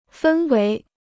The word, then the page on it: 分为: fēn wéi